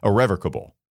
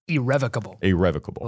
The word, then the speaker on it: irrevocable: Irrevocable. I said it wrong, didn't I? – Irrevocable.